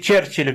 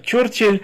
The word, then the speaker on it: Черчилль: Как все говорят, Черчилль вместо Чёрчилль, да, все говорят, да.